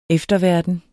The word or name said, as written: efterverden